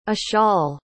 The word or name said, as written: eshal